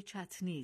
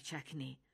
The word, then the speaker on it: چتنی: اما این کلمه در واقع چتنی است، نه چکنی. چکنی یک تلفظ عامیانه و نادرست از این کلمه است که به دلیل آسانی به کار می رود، چون در کنار حرف چه، تلفظ که از تلفظ ت آسان تر است. این تغییر تلفظ شبیه این است که ما گاهی کاغذ را کاغس یا حتی کاغس میگوییم. ما با چند دلیل بهتر است چتنی بگوییم نه چکنی.